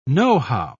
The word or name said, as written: know-how